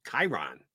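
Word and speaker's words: Kyren: It's K-Y-R-E-N. Which I believe is pronounced, you are a TV guy forever. I've heard Chiron.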